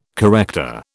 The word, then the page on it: corrector: British English